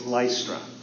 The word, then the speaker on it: Lystra: By the way, I mispronounced that city. It's Lystra.